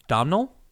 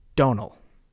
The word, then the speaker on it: Domhnall: How do you pronounce D-O-M-H-N-A-L-L? – Domnall? – You're good. You're close, but it's actually Donal. – Donal. Donal. – No M. Take the M out.